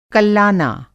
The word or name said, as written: कल्लाना